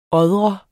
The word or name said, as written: året